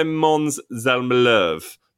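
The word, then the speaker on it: Måns Zelmerlöw: That's not how you say his surname. I never know how to say his surname properly. What would you say? Normally say Mons Zelmalov, but I don't think that's right either. – I think that's much better.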